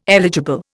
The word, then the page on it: eligible: Indian